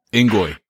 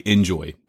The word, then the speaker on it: Engoy: Engoy. See, yeah. People probably say enjoy, don't they? – Yeah, they do. They do. – It's E-N-G-O-Y.